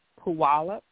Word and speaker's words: Puyallup: So it's, I tell people, I pronounce it with a W-A, so Puyallup, okay.